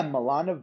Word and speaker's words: Milana: Is it Milana? – Yeah, Milana Weintraub.